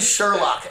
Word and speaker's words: Cziurlok: Roland Sherlock, and that is spelled C Z I U R L O K.